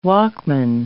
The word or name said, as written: Walkman